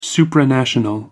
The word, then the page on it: supranational: American English